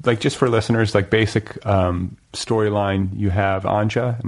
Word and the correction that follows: Anja: Am I pronouncing that right? – Anya. – Anya, yeah. Sorry. So, I'm thinking it rhymes with ganja.